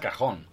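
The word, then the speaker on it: cajón: Si, chiama, si pronuncia cajon.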